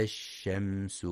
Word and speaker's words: الشمس: ينتبه القارئ في كلمة الشمس لرخاوة الشين وهمسها: إذا الشمس كورت.